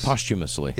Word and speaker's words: posthumously: How you say that word? Posthumously? – Posthumously. – Yeah, posthumous. There you go.